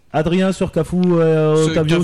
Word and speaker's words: Otavio: À noter que depuis 21h, Marc a réussi à prononcer le mot Otavio et non Octavio.